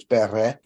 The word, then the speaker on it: Bere: If it's Old English, the last E is pronounced, so it's Bere.